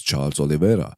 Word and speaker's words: Charles Oliveira: Und das Co-Main Event, wie schon gesagt, Charles, ja, Charles Oliveira, man sollte nicht vergessen, wie er wirklich ausgesprochen wird, und zwar The Champion has a name and his name is Charles Oliveira.